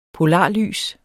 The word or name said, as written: polarlys